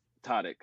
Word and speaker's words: Tareq: But it's pronounced Tarek, spelled T-A-R-E-Q. It's Arabic.